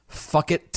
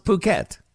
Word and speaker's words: Phuket: Number 10, fuck it, Thailand. Oh, I'm sorry, what's that? I'm told that's pronounced Phuket.